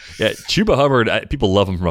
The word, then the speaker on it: Chuba: Is it Chuba or is it Chuba? I'm not even sure how to say it. – I believe it's Chuba.